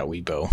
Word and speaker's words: Weibo: So, and he looks like in is it Weibo? How do you say that? – Yeah, Weibo.